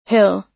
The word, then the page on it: hill: American English